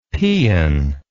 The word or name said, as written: pean